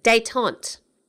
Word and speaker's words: détente: The word of the week, it sounds like al dente, but it's not. It's, I'm not even sure how you pronounce it. Détente. D-E-T-E-N-T-E. So it's spelt like al dente, but it's détente.